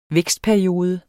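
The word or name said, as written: VÆKSTPERIODE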